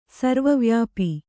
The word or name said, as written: ಸರ್ವವ್ಯಾಪಿ